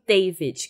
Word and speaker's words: D4vd: A gente teve a oportunidade de participar da audição do novo EP do David, que se escreve D4VD, mas é David que fala.